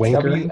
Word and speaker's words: wanker: He keeps putting an ER at the end. – That's pretty PG. – It's spelt with an ER. – It's wanker. – W-A-N-K-E-R.